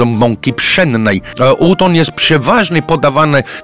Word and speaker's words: udon: Nazywa się udon. Pisze się U-D-O, jak udo, z N na końcu.